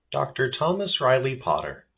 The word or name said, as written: Dr. Thomas Riley Potter